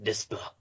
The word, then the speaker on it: Desplat: It's pronounced Desplat. – Desplat.